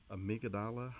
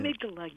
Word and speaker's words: amygdala: And one of my co-presenters, she talked about this particular amygdala hijack, if I pronounced it correctly. – And amygdala, yeah. – Amygdala, thank you.